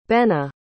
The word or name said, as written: bena